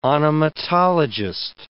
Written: onomatologist

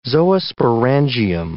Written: zoosporangium

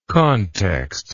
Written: contextless

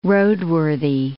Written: roadworthy

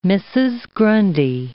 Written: Mrs. Grundy

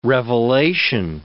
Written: revelation

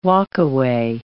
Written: walkaway

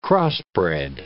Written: crossbred

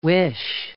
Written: wish

